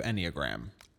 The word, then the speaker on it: Enneagram: Let's do Enneagram. – Okay. – Do you think that's how it's pronounced? – Yeah, that is for sure how it's pronounced.